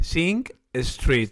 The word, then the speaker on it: Sing Street: Pero con K no, no es Sink de ya está. – Fregadero. – Sí, de la pronunciación. – Sing Street.